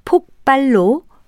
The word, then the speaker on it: 폭발: 하지만 비읍을 피읍으로 발음하지 않고 쌍비읍, 폭발로 발음해야 합니다.